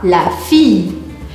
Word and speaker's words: fille: A filha, eu vou falar la fille, como se tivesse um i comprido.